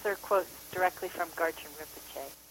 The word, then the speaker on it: Rinpoche: Her new film, called For the Benefit of All Beings, is about a gentleman named Garchen Rinpoche. I hope I said that correctly. Did I say that right, Christina? – No, it's Rinpoche. – Rinpoche.